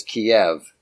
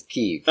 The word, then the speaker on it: Kyiv: The Russian pronunciation is Kiev, and the Ukrainian pronunciation is Kyiv.